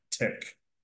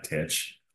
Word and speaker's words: Thich: And it's pronounced, I believe, tick, not titch. I still call him tit sometimes, but I think it's tick. You know, like your lady's kind of tick. Yeah, just like that.